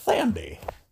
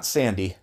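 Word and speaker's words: Thandie: I think that's Thandi, not Sandy.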